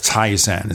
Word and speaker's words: Tizen: And they've been, what, playing around with Tizen.